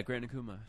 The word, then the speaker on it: Gran Akuma: Yeah, Gran Akuma.